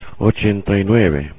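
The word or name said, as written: ochenta y nueve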